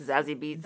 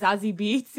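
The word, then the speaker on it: Zazie Beetz: What is her name? ZZ Beats? – Zazie Beats. – Zazie Beats.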